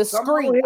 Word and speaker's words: Screet: The Screet. I'm sorry. I kept putting the T. Mr. Jay the Screet. – Yeah. We spell it like I say it. – Yeah, the Screet. And yeah. I kept saying street, you know. I saw the K, but kept saying T.